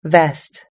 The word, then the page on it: vest: American English